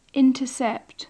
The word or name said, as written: intercept